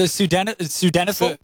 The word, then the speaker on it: sildenafil: What's the pronunciation of the chemical? – Which one? – The sildenafil? – Sildenafil. – Sildenafil. Okay.